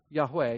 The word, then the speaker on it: Yahweh: That is the memorial name of the Lord, which most commonly is pronounced Yahweh.